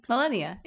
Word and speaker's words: Malenia: Malenia, yeah.